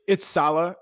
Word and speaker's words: Saleh: Do you think they would even think about going to a defensive coach, maybe like the 49ers defensive coordinator, Robert Saleh? Am I saying that correctly? – It's Saleh, and nobody knows how to pronounce that.